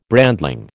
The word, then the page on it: brandling: American English